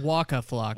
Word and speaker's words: Waka Flocka: Waka Flocka.